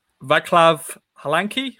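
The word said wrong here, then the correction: Václav Hladký: That's probably not. No. – Halanki. – K's fine. We're just missing a D in there, I think. – Yeah. – Hutchie, you're obviously officially the show's best pronouncer of names, particularly Eastern European names. So how should we refer to Town's new number one? What's his name? – It's Vaclav Hladky.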